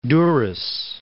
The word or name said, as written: Durres